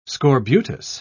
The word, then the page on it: scorbutus: British English